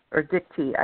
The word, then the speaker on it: Dicte: So there's the cave on Mount Dicte, which is Dicte, I think I know ancient Greek, and that's a completely different pronunciation system than modern Greek.